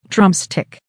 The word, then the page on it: drumstick: American English